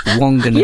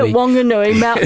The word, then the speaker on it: Whanganui: For our overseas listeners, that's Whanganui. – Yeah, Whanganui, Māori pronunciation.